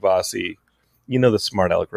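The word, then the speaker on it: Vasi: Wait, so is her name Vase? – Vossi. Yeah, it's...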